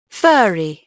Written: furry

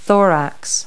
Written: thorax

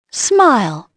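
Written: smile

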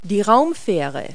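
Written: space shuttle

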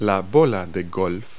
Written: golfball

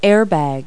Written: airbag

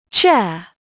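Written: chair

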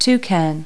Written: toucan

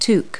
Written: toque

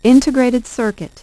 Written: integrated circuit

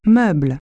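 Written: furniture

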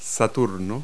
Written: Saturn

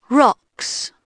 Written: rocks

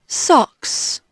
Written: socks

